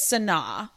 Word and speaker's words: Sanaa: Animal Kingdom, we are actually also transferring over to the Animal Kingdom Lodge and doing Sana. Is that how you pronounce it? – Sanaa.